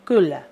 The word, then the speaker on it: Kula: Pero porque vemos la U. Pero la verdad es que es Kula. ¿Ya? Kula.